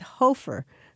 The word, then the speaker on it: Hoffer: He said Hofer instead of Hoffer.